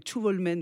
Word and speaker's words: Tjuvholmen: Tjuvholmen, donc je ne parle pas suédois, mais Tjuvholmen, voilà, ça, normalement ça se prononce comme ça.